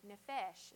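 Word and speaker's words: Nefesh (נפש): Nefesh is how you say that.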